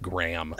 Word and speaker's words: Graham: Graham, I'm sorry, Graham. I want to say it right. I keep doing the Americanized Graham. That's not fair. You're not a measurement of mass or weight.